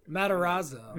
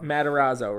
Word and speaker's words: Matarazzo: How do you say her name? – Matarazzo. – Matarazzo, right? – Okay, yeah.